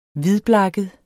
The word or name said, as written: hvidblakket